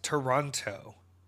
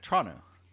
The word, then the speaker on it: Toronto: It's not pronounced Toronto. It's pronounced Chano.